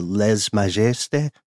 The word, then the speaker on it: lèse-majesté: Their government is extremely different from the West, especially in their practice of, help me with the French pronunciation here, the lèse-majesté or lèse-majesté. the Rights of the King. – Guessing for a silent S there, but that's my only critique.